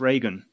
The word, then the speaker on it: Rogan: Second up, we're going to thank Anthony Rogan. Thank you, Anthony. – Thank you, Anthony. Appreciate it. – I must say on Anthony's behalf, it's actually pronounced Reagan.